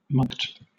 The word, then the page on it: mulct: British English